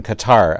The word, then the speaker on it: Qatar: And members of Congress are watching, and they do appear right now to want to hold the Qataris to account. – I'm saying Qatar and Qatar. I should. which is it? – Qatar. That's how I think they pronounce it.